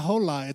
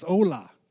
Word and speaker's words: hola: He's like, no, it's not hola, it's hola. The H is silent.